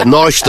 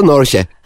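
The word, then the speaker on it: Porsche: Norş, o Norş diye mi okunuyor, Norş diye mi? – Senin cebindeki paraya bağlı. Yani eğer az param varsa Norş, gerçekten onu alacak param varsa Norşe.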